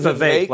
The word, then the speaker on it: Vivek: Is it Vivek like cake? – Yes. – Is it Vivek like cake? I apologize. Yeah. So it's Fake Vivek. – Rhymes, you see.